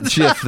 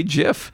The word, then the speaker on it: GIF: Oh, does he actually pronounce it GIF? – Yeah, that's where I learned how to say GIF. He always said it's GIF. I'm like, what an idiot. It's clearly a hard G.